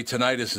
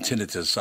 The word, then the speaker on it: tinnitus: You know, you and I didn't talk about, people say tinnitus and tinnitus. I think it's tinnitus, isn't it? – Doctors pronounce it tinnitus. – They do, yes. – Tinnitus is also an acceptable pronunciation, and to me, that's actually more accurate because tonight it's us every night.